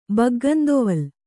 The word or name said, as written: ಬಗ್ಗಂದೊವಲ್